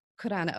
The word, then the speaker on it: Khurana: Khurana, okay. Because KH, it's like my name, but we say... Exactly. – Like Arabic, so, yes.